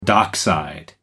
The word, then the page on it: dockside: American English